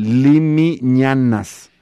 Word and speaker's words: Limiñanas: Ándale, que por fin me tocó oír esta grabación del grupo Limiñañas. De ahora lo digo mal, Limiñanas.